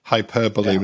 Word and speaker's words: hyperbole: I was 18 before I'd understood that hyperbole was pronounced, not pronounced hyperbole.